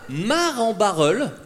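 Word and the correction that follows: Marcq-en-Barœul: Marc en Barreul. – Marc, putain!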